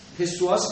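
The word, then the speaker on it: psoas: یه عضله ای داریم از مهرهایی که کمری میاد پایین میگن سواس ماژور پسواس میدونیسن ولی پیش رو تلفظ نمیتونن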